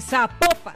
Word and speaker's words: Zapopan: Oiga, nos escriben, escriben los oyentes y que no se, pronuncia Zapopan, sino que se pronuncia Zapopan.